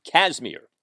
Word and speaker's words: Kazmeir: The first name is spelled K-A-Z-M-E-I-R, pronounced Kazmir.